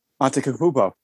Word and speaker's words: Antetokounmpo: So can you say his last name by any chance? – Antetokounmpo. – Is that how you say it? – Yeah.